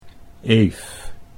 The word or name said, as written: Eef